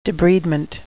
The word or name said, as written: debridement